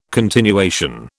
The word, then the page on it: continuation: British English